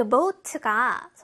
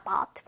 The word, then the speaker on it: bought: 이 bought가 선생님이 bought라고 발음을 하고 bought, 이것도 됩니다. bought, bought 다 되는데 bought 같은 경우는 뭐 미국식 bought 하면은 조금 더 뭔가 정확하게 발음하려는 영국식 발음에 가까워요. 둘 다 맞는 발음입니다. I bought roses.